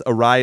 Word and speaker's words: Errai: And just so everyone knows, Arai is spelled E-R-R-A-I.